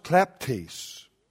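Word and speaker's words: kleptes (κλέπτης): Taking the New Testament word, the Greek word, it is pronounced kleptes. And it is from that word that we get the English word kleptomaniac.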